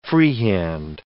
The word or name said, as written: freehand